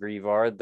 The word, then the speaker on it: Greavard: I'm assuming it's pronounced Grievard, the ghost puppy Pokemon, because Niantic just wanted to make me cry and make a dead dog.